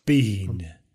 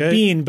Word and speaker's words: Biehn: Bean. Like bean, but with an H before the end. Bean. – Yeah, turns out it's bean.